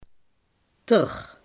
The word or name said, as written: er